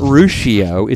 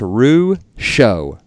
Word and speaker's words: Ruscio: So it's not Ruscio, it's Ruscio. Ruscio. So Dr. Ruscio is on the show. – So we've been saying it wrong the whole time.